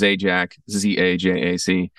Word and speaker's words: Zajac: So my actual last name is Zajac, Z-A-J-A-C. And I mean, I'm used to it, so I never thought it was hard to pronounce, but people are always like, how do you say Zajac?